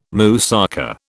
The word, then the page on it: moussaka: British English